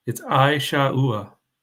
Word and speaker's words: Esau: Pam put how to pronounce Esau. It's Aishaua, if that helps.